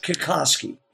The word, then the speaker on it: Kikoski: Kikoski. Am I pronouncing that correctly? – That's correct. Kikoski.